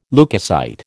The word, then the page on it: leukocyte: American English